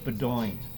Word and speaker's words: Beaudoin: So, but then again, you know, I mean, the New England Patriots had a player on their team years ago, and they called him Doug Bedoin. B-E-A-U-D-O-I-N. Bedoin. – Bedoin? – Baudouin.